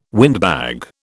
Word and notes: windbag: British English

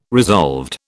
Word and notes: resolved: British English

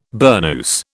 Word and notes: burnous: British English

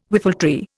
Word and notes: whiffletree: Indian

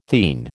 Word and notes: theine: American English

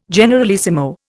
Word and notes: generalissimo: Indian

